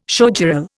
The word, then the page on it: showgirl: Indian